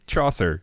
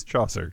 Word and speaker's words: Chaucer: Geoffrey Saucer. – Chaucer. It's Chaucer.